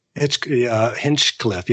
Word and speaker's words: Hinchcliffe: A side note to that, I think, how do you pronounce his name? Hinchcliffe? – Hinchcliffe. Yeah.